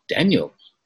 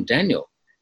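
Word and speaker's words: Danial: and then I found out my name's Daniel, and it was like, well, that's news to me.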